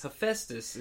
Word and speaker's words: Hephaestus: Hephaestus is how you say that.